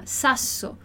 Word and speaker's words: sasso: Já a palavra sasso é uma palavra que começa com S. Então, esse S no início da palavra, a gente vai pronunciar dessa forma, sasso. Como no português, se a gente pensar na palavra sábio, por exemplo, é uma palavra que começa com S-A, sa. Então, a gente vai ler com esse som de S mesmo.